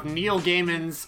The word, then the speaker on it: Gaiman: How about we talk about Netflix's rendition of Neil Gaiman's, I said it right that time, rendition? – Sandman.